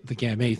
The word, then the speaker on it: Gamay: This week, the tea is silent, Monsieur Gamet. – So, Doc, I actually have a story to share with you about the Gamay thing.